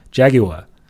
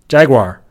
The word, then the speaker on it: Jaguar: 那英国人是怎么读？英国人读成捷豹这个词是读成 Jaguar。美国人怎么读呢？就变成了美国的儿化音大卷舌这种读法，就变成了 Jaguar，就听起来感觉有点像摘瓜一样，感觉你不是那么的文气啊，听起来也不是那么的绅士。